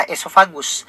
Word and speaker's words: oesophagus: Tulisannya adalah oesophagus, cuma dibacanya adalah esofagus.